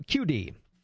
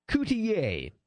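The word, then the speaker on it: Cutié: Now, originally I called him Father Alberto QD, because that's the way it's spelled, but it's pronounced Coutier. He likes the French pronunciation.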